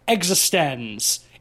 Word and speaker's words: eXistenZ: That's the first line of the movie after the credits is eXistenZ. It's written like this, capital X, capital Z. So immediately you know how to pronounce the name of the film if you were uncertain.